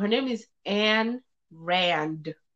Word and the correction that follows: Ayn Rand: Ayn Rand. It always rolls up because I always miss up a letter or something. But she is Ayn Rand.